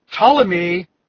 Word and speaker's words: Ptolemy: And Ptolemy, and that starts with a P, but the P is silent. Like for those who wear diapers, the P is silent.